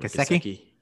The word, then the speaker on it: Gesicki: and Mike Gesicki. Yeah, I can never say his name. – Gesicki? – Yeah, there we go.